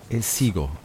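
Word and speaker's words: Elciego: Die Bodega Marquez in, äh, wie hast du es gerade ausgesprochen? – El, El Ciego. – El Ciego, okay.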